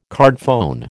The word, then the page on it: cardphone: American English